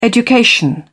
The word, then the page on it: education: British English